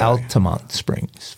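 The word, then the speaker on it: Altamonte Springs: Alamonte Springs, Florida, to be exact. – Altamont Springs, Florida.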